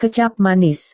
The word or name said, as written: kecap manis